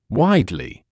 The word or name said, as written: widely